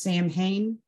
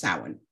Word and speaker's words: Samhain: Um, some people mispronounce it, uh, or you might read it as Samhain, but it's really pronounced Samhain.